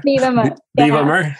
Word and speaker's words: Vivomer: I've seen on your website, I don't know if I'm saying it right, Vivomer? – Vivomer, yeah.